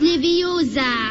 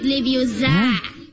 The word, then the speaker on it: Leviosa: Und übrigens hast du die falsche Betonung. Es heißt Leviosa und nicht Leviosa.